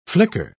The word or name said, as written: flicker